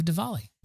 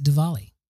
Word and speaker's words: Diwali: It's so weird because they, when they tell us in at work, when our Indian coworkers are telling us, they don't pronounce it Diwali. They call it Diwali.